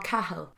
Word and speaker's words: Cahill: So do you say Cahill, not Cahill? How does he spell his name? – He pronounces it Cahill. – C-A-H-I-L-L. – See, I pronounce that Cahill.